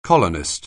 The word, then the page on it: colonist: anglais